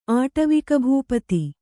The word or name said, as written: ಆಟವಿಕಭೂಪತಿ